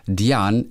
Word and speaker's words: Diane: Denn im PS heißt es, habe ich mich richtig daran erinnert, Diane, es ist stumm, französische Wurzeln. – Diane, okay. – Diane geschrieben, aber Diane ausgesprochen.